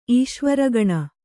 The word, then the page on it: ಈಶ್ವರಗಣ: īśvaragaṇa; Kannada